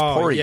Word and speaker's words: Poirier: That's what it looks like, but it's Poirier.